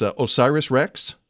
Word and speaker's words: OSIRIS-REx: Now, first of all, we need to know how to pronounce this thing. It's OSIRIS-REx. Is that right? – That's correct. That's okay.